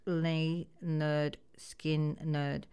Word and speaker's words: Lynyrd Skynyrd: It's been 53 years ago yesterday on the 13th of August that Lynyrd Skynyrd released their debut album, pronounced Lynyrd Skynyrd.